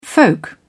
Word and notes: folk: British English

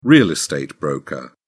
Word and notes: real estate broker: British English

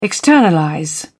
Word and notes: externalize: British English

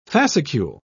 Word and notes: fascicule: British English

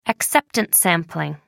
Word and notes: acceptance sampling: American English

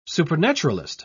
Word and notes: supernaturalist: British English

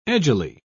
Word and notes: edgily: British English

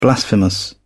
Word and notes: blasphemous: British English

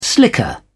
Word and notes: slicker: British English

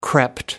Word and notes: crept: American English

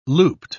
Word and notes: looped: British English

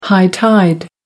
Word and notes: high tide: American English

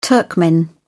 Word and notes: Turkmen: British English

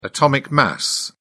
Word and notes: atomic mass: British English

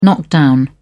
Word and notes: knock-down: British English

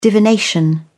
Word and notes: divination: British English

